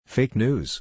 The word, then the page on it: Deepfake: English